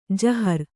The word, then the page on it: ಜಾಹಿರು: Kannada